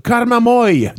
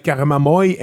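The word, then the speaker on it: Karmamoi: Karmamoy de l'Italie. Ça devrait être comme ça que ça se prononce. Karmamoy, M-O-I.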